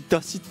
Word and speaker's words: 出汁: 広島県産牡蠣の旨味が凝縮した新ご当地ラーメン、特産の大粒牡蠣から抽出した濃厚だし、これ出汁と書いてだしって読むんだよみんな知ってたから、広島の海の恵みが凝縮されております。